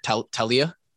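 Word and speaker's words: Taulia: How do you say it? Talia. – Yep.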